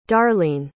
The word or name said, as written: darlene